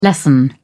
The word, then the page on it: lessen: British English